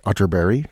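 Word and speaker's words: Atterberg: It's spelled Otterberg, but pronounced Otterberg, just in case you were Googling.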